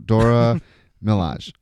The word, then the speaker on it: Dora Milaje: So there's this trouble in his personal guard, and then we find out that his personal guard are Dora Milaje or Milaje. I don't know if you pronounce the E. – Okay. – But think Nicki Minaj. So Dora Milaje.